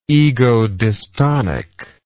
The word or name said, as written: egodystonic